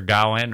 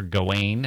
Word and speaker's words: Gawain: And Sir Gawain, or Sir Gawain, however you pronounce it. I mean, it's pronounced both ways and by different people.